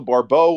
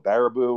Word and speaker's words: Baribeau: So Rachel Barbeau will be very happy that I pronounced her last name correctly, because unfortunately too many people botch it and call it Baribeau or all these other different ones.